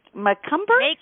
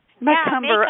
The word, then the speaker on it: Macomber: And oh, Debbie, it's your last name is pronounced like cucumber, you said, right? – Right. – Macomber? – Macomber.